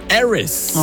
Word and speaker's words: Aris: Und wo sie neu war, hat sie immer gesagt, Tiffany Aris, natürlich Aris. Deutsch ausgesprochen. – Mittlerweile ist es Tiffany Aris. – Ah, international.